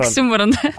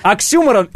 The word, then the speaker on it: оксюморон: Оксюморон. – Оксюморон. И, кстати, не оксюморон, а правильно говорить оксюморон.